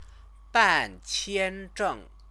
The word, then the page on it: banqianzheng: chinese